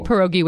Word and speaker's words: pierogi: Go say pie. – Pie. – Ro. – Ro. – Gi. – Gi. – Pierogi. – Pierogi. Did I do it? Okay, good. – Perfect. Pierogi.